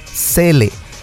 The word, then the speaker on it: Zelle: Se llama Cele y se escribe Z-E-L-L-E.